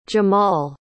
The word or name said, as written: jamahl